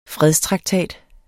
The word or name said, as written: FREDSTRAKTAT